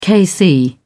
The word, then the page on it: kc: British English